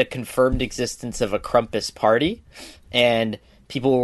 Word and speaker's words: Krampus: Krampus. – Krampus.